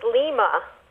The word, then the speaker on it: Lima: So, it's Lima, Peru. – Oh, is that what they say? – Huh? – Is that what they say? – Yeah. – Then why isn't it... – I is pronounced E.